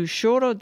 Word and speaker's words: choro: We're going to Choro das Três, three-sister Brazilian instrumental music group which plays a style known as choro, choro, choro. And it's pronounced Choro, Choro.